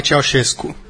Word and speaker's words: Ceaușescu: por favor, a pronúncia, Nicolae Ceaușescu. – Eu, como todo mundo fala, Ceaușescu. – Eu falo Ceaușescu.